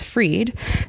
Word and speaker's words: Frid: I knew that I wanted it to be my name, but Frid is, you know, fairly generic and nobody really knows what it is. And then when I realized I could just switch it to the proper pronunciation, which is Frid, suddenly Frid Events actually was perfect and it described my company perfectly.